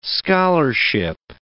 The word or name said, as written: scholarship